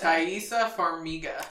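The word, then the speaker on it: Taissa Farmiga: He actually gets, how do you say her name again? Thaisa? – Thaisa Farmiga.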